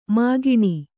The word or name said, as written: ಮೈಗಣ್